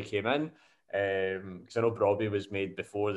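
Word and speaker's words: Brobbey: How do you say that? Is it Brobby or Broby? Or is it like Bobby with an R? – Yeah, Brobby.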